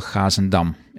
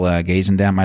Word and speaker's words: Gazendam: His name was Marcel Gazendam, if you want to pronounce it in Dutch. You know, in English it would be like Marcel Gazendam, I don't know.